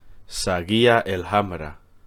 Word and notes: Saguía el Hamra: Spanish

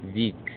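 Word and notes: Vik: Icelandic

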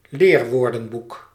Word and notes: leerwoordenboek: Dutch

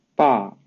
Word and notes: 爸: Mandarin Chinese

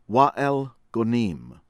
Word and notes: Wael Ghonim: American English

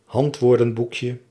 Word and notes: handwoordenboekje: Dutch

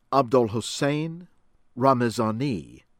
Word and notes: Abdolhossein Ramezani: American English; from Iran

